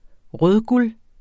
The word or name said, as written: rødguld